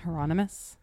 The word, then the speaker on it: Hieronymus: The artist's name is Hieronymus Bosch. I probably said that wrong. – Is it with a J like Geronimo? – Yeah, it's J-H-E-R-O-N-I-M-U-S. – Okay. – Hieronymus.